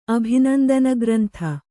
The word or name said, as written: ಅಭಿನಂದನಗ್ರಂಥ